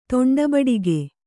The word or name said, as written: ತೊಂಡಬಡಿಗೆ